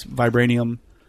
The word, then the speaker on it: vibranium: Vibranium or...